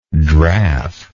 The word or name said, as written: draff